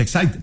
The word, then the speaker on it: Exide: Como quiera, Hormuz u Hormuz, dentro se escribe Exide, se pronuncia Excite, dentro decía el comercial de las baterías.